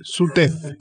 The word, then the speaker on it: SUTEF: que es el secretario de organización del SUTREF, del Sindicato de Docentes de Tierra del Fuego. Esta vez lo dije bien, ¿no? – No, es SUTEF. – SUTEF. Ah, yo le agrego una R.